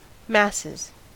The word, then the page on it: masses: American English